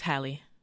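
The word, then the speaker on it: Halle: Because of Haley Bailey. I mean, she was a force. – Mm-hmm. – A supernova. – Let's stop butchering her name. It's Hallie.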